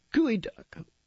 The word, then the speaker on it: geoduck: And then in Washington, we have gooey duck. Well, it looks like geoduck, but even its pronunciation sounds suspect, gooey duck.